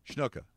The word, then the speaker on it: Snuka: Snuka. I threw in an H.